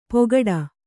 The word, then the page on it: ಪೊಗಡ: pogaḍu